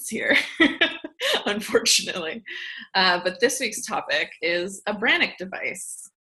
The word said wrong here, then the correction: Brannock: It's spelled B-R-A-N-N-O-C-K.